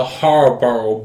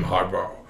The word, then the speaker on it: Harbaugh: John Harbaugh versus Jim Harbaugh. Brother versus brother. – How do you say his name again? – Harbaugh. H-A-R-B. – Harbaugh. – Right there. Right there. It's H-A-R-B-A-U-G-H. Harbaugh.